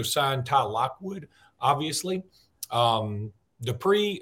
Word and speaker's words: Dippre: Uh, so, Jimmy, CJ Dippre, that's how you pronounce that, D I P P R E,